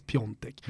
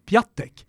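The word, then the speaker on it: Piątek: Och så finns det då en eh, polsk anfallare idag som stavar Piatek, men ska uttalas Piontek. – Mm. Ska man då uttala det här Piatek? – Se, så blir, blir det omvänt, Sepp Piatek?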